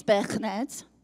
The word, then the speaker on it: Birkenhead: And I was born in a little town called Birkenhead, which if I said it properly is Birkenhead.